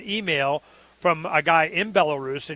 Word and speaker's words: Belarus: So, Belarus, is that how you say it? – Belarus.